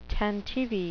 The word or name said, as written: tantivy